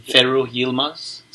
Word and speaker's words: Ferruh Yilmaz: Ferruh Yilmaz.